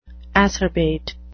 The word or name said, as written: acerbate